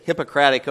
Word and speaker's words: Hippocratic: He has taken the Hippocratic Oath. Is that pronounced correctly? – Yes. – It almost sounds like hypocrite, but that's not what it means.